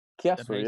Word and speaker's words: Chiasso: Is it FC Chiasso? – Chiasso, yeah. Exactly. – Chiasso, yeah, yeah.